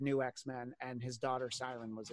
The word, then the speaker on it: Siren: Siren is pronounced Siren. I read it wrong.